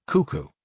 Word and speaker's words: CUCU: The first system was called CUCU, pronounced Cuckoo.